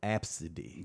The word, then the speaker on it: Abcde: And that's not good, but mine's is Absidy. – That's how they say it? – That's how it's pronounced. Yes, Absidy.